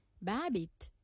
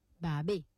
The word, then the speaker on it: Babit: En créole, nous pouvons prononcer Babit. Mais en français, bien sûr, nous pouvons prononcer Babé.